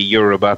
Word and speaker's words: Yoruba: Is it Yoruba? Is that how you pronounce it? And if you could speak to that a little bit, that would be wonderful. – Just about right. The Yoruba people.